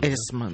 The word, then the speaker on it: Эсма: Эсма, я ударение правильно ставлю. – Эсма, да.